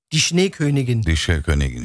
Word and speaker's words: Die Schneekönigin: Ondan sonra The Schneekönigin. – Die Schneekönigin işte bunları öğrenmemiz lazım telaffuz etmeyi.